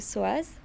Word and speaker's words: Soaz: Alors mon nom dans la fresque, dans le street art, c'est Soaz, donc ça s'écrit S-O-A-Z.